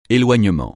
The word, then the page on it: éloignement: francais